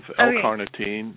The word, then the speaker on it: L-carnitine: You said L-carnitine. Am I saying that right? I hope. – Yeah, of L-carnitine.